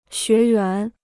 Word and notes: 学员: xué yuán

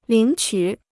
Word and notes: 领取: lǐng qǔ

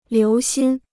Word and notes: 留心: liú xīn

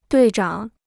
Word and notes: 队长: duì zhǎng

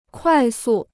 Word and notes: 快速: kuài sù

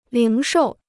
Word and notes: 零售: líng shòu

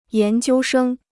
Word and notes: 研究生: yán jiū shēng

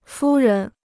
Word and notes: 夫人: fū ren

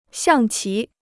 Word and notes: 象棋: xiàng qí

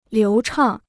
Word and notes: 流畅: liú chàng

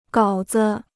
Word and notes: 稿子: gǎo zi

